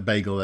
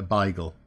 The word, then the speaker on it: beigel: So anybody who's non-Jewish and calls it a bagel, that's absolutely fine. That is a reasonable pronunciation. If you have any East London Jewish heritage, you're likely to call it a beigel, B-E-I-G-E-L.